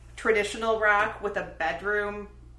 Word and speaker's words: BDRMM: The first album I want to talk about is a shoegaze band from the UK called Bedroom, but it's spelled BDRMM.